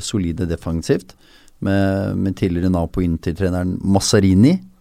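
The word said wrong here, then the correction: Mazzarri: er det riktig uttalelse? Masari. Masari. Um,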